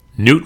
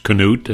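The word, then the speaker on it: Knute: Well, at the top, Newt Rockne, or as is correctly pronounced, Canute, as you do in the movie George Gipp All-American. They call him Canute. That's the Norwegian pronunciation. I always preferred it, but a lot of people say Newt, Newt Rockne.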